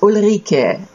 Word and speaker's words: Ulrike: My name is Ulrike. You pronounce it kind of like Eureka, which may be an easy way out for you cheaters out there. For those of us who can't roll that R.